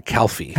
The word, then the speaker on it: Calfy: Not like a cow calf. – No. – C-A-L-F-Y. – Yeah, it's spelled that way, but it's said Calfee.